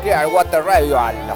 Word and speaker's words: Clearwater Revival: Nos vamos de la mano de los Clearwater Revival, así, ¿lo dije bien? – Perfecto.